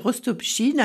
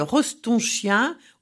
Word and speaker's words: Rostopchine: On n'arrive pas à dire Rostopchine, alors on l'appelle Rostonchien ou Prince de Chine.